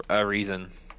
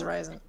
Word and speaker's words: Arizin: You got Paul Arizin. I don't know if I'm saying that right. – Paul Arizin. – Arizin. Rick Barry. I think it's Arizin.